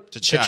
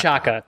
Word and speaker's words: T'Chaka: T'Chaka. – Killing T'Chaka. – T'Chaka.